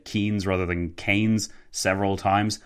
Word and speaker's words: Keynes: I said John Maynard Keynes several times.